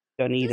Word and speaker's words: Dunedin: You're literally going to a place that is spelled the same as Dunedain, and I'm like, Dunedin. – This is awesome, but it's Dunedin? Is that what you say? – Yeah.